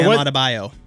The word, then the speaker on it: Adebayo: Bam Adebayo.